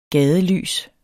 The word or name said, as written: gadelys